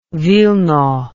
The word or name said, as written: Wilno